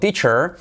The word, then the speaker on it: teach: Esa sí que es una I. Y curiosamente se escribe con una E, TH, pero se dice I. Teach. Y una I española, I sin miedo, una I, teach.